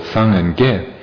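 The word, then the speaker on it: sang and ge (སངས་རྒྱས): So Buddha in Tibetan, the word for Buddha has two syllables, sang and ge.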